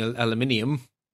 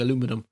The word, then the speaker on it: aluminium: Stumbling over saying it the European way. I have been listening to too many American podcasts. It causes me effort to say it correctly. I keep on wrongly saying aluminum in my head. Aluminium.